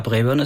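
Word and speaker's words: Braeburn: Ja, Brebern ist schon richtig ausgesprochen.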